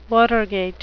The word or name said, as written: Watergate